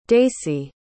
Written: daisey

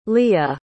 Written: lige

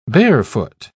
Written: barefoot

